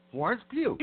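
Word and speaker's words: Florence Pugh: Florence Pew.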